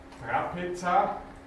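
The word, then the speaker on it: τράπεζα: Betonung über dem ersten Alpha. Trapeza kennen Sie sicher vom Wort Trapez.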